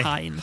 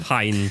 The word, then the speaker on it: Hein: Det blir ju något svenskt uttal på sista ordet där. Jag kan inte säga the damned house of Hain. utan det Nej, Hain. Hain tror jag det är också mer, rätt. korrekt.